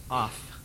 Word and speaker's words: aph: In the Old Testament, the word that primarily is used for anger is a word that is pronounced aph, A-P-H, and it's an interesting word.